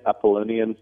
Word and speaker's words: Apollonian: But I guess one big varying, like in Nietzsche's Birth of Tragedy, and comparing the Dionysian ethic versus the, how do you say it, Apollonian? – Close enough.